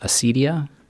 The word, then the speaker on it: acedia: It's akidia, which I think is sometimes pronounced acedia in English, A-C-E-D-I-A.